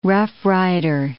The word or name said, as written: roughrider